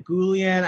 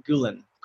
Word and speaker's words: Guillén: Um, so it's like Goulian. I remember being in school and like, you know, uh, being mispronounced like all the time. They were just like Goul Goul Goulin. Goulin? Is it Goulin? And it's like no. It's like the double L makes a sound.